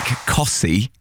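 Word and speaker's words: Costessey: When I used to do work experience at BBC Norfolk, getting some of the texts and calling people back from places like Cossey, but it's spelt Cottesy, is...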